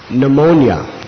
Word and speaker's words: pneumonia: Just like the word pneumonia, it begins with a P. If you were to say to someone the pronunciation that's actually correct, They would think you're illiterate if you said P. pneumonia.